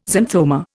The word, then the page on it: xanthoma: Indian